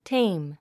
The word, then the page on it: tame: téim; テェム